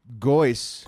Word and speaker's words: Goyce: Goyce. – It's like almost like moist.